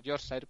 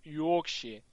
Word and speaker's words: Yorkshire: También he tenido el placer de visitar el, el, Yorkshire, pero se pronuncia Yorkshire.